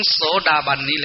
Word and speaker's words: โสดาบัน: รรมอันเป็นองค์ประกอบให้เข้าถึงโสดาบันโสดาบันเป็นอริยชนกลุ่มชนที่เป็นกลุ่มชนในอุดมคติของพุทธศาสนากลุ่มแรกกลุ่มแรกถ้าเข้าถึงโสดาบันนี้